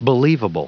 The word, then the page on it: believable: anglais